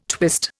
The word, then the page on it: twist: Indian